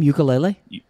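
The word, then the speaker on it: Uiagalelei: Is that how you say his name? Ukulele. – Yeah, DJ Ukulele.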